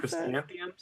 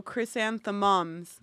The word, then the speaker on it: chrysanthemums: I don't even know what that chrysanthemums? – Said. That's the one. – Yeah. There we go. – But it's spelled weird. It's like, oh, chrysanthemums. Like mums.